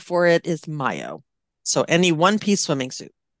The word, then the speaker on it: maillot: So mayo, which is spelled M-A-I-L-L-O-T, like I would just say, oh, it's a malot. – Or yeah. – Malot. Yeah, it's pronounced mayo.